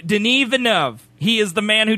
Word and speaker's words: Denis Villeneuve: His name is Denis Villeneuve.